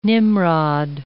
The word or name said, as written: Nimrod